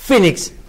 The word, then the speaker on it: Phoenix: Recordemos que decimos Cash, Phoenix porque Phoenix. Sabemos pronunciar bien el inglés. – Obviamente, pero es no estudié el pedo de los 15 años de mi vida. Inglés. – Es P-H-O-N. No, E-N-I-X. Phoenix.